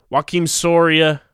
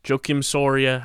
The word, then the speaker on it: Joakim Soria: Joaquim Soria, Joakim Soria, however you want to pronounce it, I say Joaquim, traded to the Toronto Blue Jays for two players to be named later from the to the Arizona Diamondbacks.